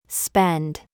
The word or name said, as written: DRESS